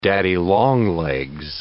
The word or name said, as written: daddy longlegs